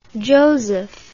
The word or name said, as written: josef